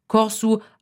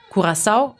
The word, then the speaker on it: Curaçao: Die Aussprache ist dementsprechend unterschiedlich. Corsu auf Papiamento, Curaçao auf Spanisch und Portugiesisch, Curaçao auf Niederländisch.